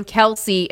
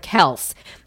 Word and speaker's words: Kelce: Turns out Ed just got tired of correcting people who mispronounced it, so it just became Kelce instead of Kels.